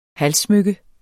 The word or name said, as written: halssmykke